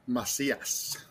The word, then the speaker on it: Macias: Is it pronounced Macias? – No, Macias.